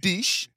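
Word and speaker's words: Diesch: While we talked about the depth there, I don't know if this guy stood out to you, but Kellen Deesh, as I believe how, because if it's pronounced the other way that my brain wanted to pronounce it, we may get canceled on here. But Kellen Deesh, I believe is how you pronounce his name.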